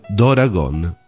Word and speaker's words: Doragon: vede la pronuncia giapponese dell'inglese dragon diventare Doragon, da cui Tora Dora.